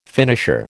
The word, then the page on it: finisher: American English